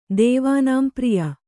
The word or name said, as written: ದೇವಾನಾಂಪ್ರಿಯ